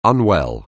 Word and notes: unwell: anglais

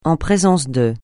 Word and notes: en présence de: français

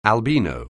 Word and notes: albino: English

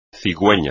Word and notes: cigüeña: espagnol

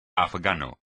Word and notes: afgano: espagnol

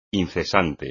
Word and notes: incesante: espagnol

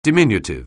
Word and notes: diminutive: anglais

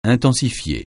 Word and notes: intensifier: francais

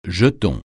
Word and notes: jeton: francais

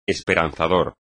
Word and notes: esperanzador: espagnol

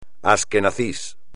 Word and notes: asquenazi: espagnol

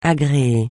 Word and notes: agréer: French

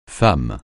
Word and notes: femme: francais

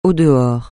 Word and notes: au-dehors: francais

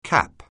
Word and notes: CAP: anglais

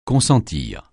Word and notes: consentir: francais

